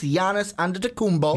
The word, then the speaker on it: Giannis Antetokounmpo: See, I didn't do the hard G. Yep. I didn't give you the Giannis. No, it's Giannis Antetokounmpo.